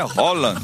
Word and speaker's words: Haaland: Ele falou: a pronúncia do meu nome é Haaland. Haaland, Messi, Cristiano Ronaldo.